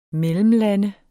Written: mellemlande

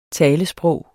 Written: talesprog

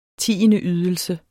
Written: tiendeydelse

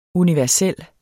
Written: universel

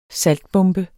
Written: saltbombe

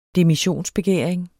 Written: demissionsbegæring

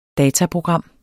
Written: dataprogram

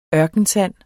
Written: ørkensand

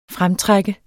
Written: fremtrække

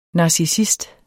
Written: NARCISSISTEN

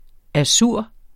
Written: azur

